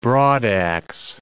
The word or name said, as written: broadax